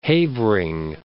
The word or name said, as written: Havering